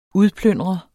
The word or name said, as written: udplyndre